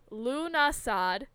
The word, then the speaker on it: Lughnasadh: So it's Lunasad, but it's spelled Lughnasadh.